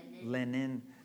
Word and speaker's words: Lenin: Is that his name? Is that how you pronounce it? – Lenin. – And not John Lennon.